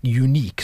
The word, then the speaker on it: Yuneec: Unique. So it's spelled Y-U-N-E-E-C.